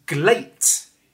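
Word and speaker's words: Gleat: That Sho is taking part in Glate.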